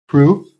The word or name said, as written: PROOF